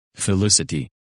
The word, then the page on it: Felicity: English